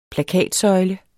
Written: plakatsøjle

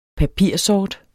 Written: papirsort

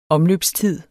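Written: omløbstid

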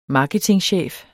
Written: marketingchef

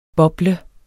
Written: BOBLEDES

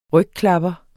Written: rygklapper

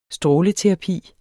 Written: stråleterapi

